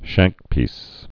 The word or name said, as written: shankpieces